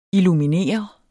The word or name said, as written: illuminere